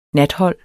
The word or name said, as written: nathold